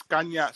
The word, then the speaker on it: Canha: También la botó Caña. Eso es Caña, Canha, como se escribe en portugués.